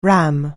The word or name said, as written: RAM